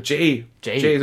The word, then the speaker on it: Jay: Jay Baruchel, würde ich mal aussprechen. – Jane Baruchel. – Jay, so wie Jay der Silent Bob.